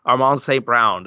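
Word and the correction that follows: Amon-Ra St. Brown: Did I say it right that time? – Yes, you forgot the Ra. Amon Ra St. Brown. You're good otherwise.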